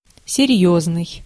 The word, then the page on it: серьёзный: /sʲɪrʲˈjɵznɨj/